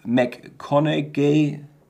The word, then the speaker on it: McConaughey: Und zwar ist es Camilla und Matthew McConaughey. Ja, so wird er ausgesprochen.